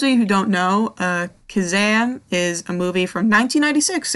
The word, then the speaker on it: Kazaam: I think it should be pronounced Kazam because there are two A's.